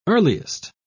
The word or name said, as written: earliest